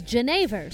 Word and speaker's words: Genever: calling it Genevieve? Well, I just looked that up and it's Genever, so I really Fucked up that pronunciation.